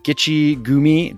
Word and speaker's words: Gichigumi: He's the executive director of the Gichigumi. Did I pronounce that right? – Yep. Good. You sound like a local who everybody mispronounces it. So it's right on. You're right on.